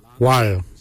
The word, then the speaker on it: waly: Lungs, we say wal. Wal is your lungs. Wal is spelled W-A-L-Y.